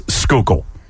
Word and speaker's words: Schuylkill: S-C-H-U-Y-L-K-I-L-L, Schuylkill. – Nailed it, Bob Cooney. – Yeah, well, here is Boban Marjanovic and Tobias Harris attempting to pronounce Schuylkill.